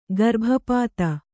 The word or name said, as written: ಗರ್ಭಪದಿ